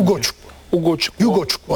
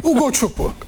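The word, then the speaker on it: Ugochukwu: Yani Dani Olmo Foden finalde Ogocukwu olunca doğru mu telaffuz ettim? – Ugoçukwu hatta. – Leslie diye gitsin ya. – Herkes öyle diyor zaten. – Leslie. – Yani Leslie diye. – Doğru. – Ugoçukwu.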